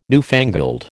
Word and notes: newfangled: American English

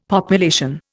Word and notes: population: Indian

